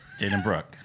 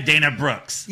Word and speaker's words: Dana Brooke: almost 5 years, and you still pronounce Dana Brooke's name wrong. – Yeah. – Dana Brooke. – Yeah, hey! Dana Brooke. Yeah. – You just said Dana Brooks.